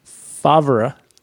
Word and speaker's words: Favre: Yeah, the etymology of Favre is interesting to me because it's spelled F-A-V-R-E, Favre. – But not pronounced. – Yeah, it's pronounced Favre.